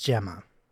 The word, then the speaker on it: Gemma: Is it Gemma or Gemma? – I believe it's Gemma.